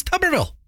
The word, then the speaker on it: Tuberville: Is it Tuberville or Tuberville? – It's Tuberville. Coach Tuberville.